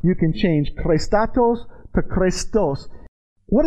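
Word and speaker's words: chrēstotēs: So the word goodness is actually pronounced like this, Christatos.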